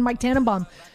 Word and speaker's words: Mike Tannenbaum: Marcel, thank you so much for joining myself and Mike Tannenbaum this morning on New York Game Day.